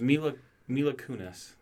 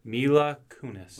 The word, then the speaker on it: Mila Kunis: I was just looking up, it's Mila Kunis.